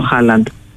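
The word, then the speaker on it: Haaland: Βρε τον, ποιος είναι, και λέγανε Αλάντ, βρε ποιος είναι ο Αλάντ, και μετά καλωδίωσα μετά από την επόμενη μέρα ότι ήταν ο Χάλαντ. Αλλά έτσι τον προφέρουν οι Γάλλοι.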